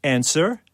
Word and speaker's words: answer: Or answer? – P. W. – W, yeah.